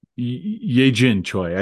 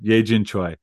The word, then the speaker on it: Yejin Choi: I don't know that I'm pronouncing that right, but I think it was something... Yejin Choi. I just looked it up. Yejin Choi.